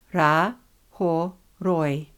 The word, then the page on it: Rahoroi: Ra–ho–roi